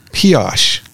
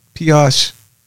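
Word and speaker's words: Pesach: You know, because cleaning products, they're having special offers and sections of kosher for Piash. Did I pronounce that right? – Yes, you're good. – Piash. P-E-S-A-C-H. Y'all pronounce it for me.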